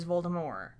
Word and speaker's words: Voldemort: Well, the first time I read the book, I read it as Voldemort because of the French pronunciation.